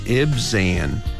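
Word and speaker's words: Ibzan: 먼저 입산을 영어로는 이렇게 발음합니다. 잘 듣고 따라해 보시기 바랍니다. Ibsan. IB이기 때문에 Ib. Ib. 그리고 Zan. Ibsan.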